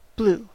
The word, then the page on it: blew: American English; /bluː/